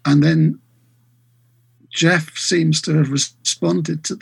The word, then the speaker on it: Gef: Um, he couldn't spell, uh, so it was phonetically G E F. It's uh, Jeff.